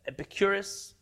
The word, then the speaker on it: Epicurus: There was a famous man named, in Hebrew we call him Epicurus. I think in English they call him Epicurus, could it be that I'm pronouncing correctly? – Yes.